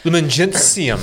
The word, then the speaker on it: Lumen Gentium: Lumen Gentium.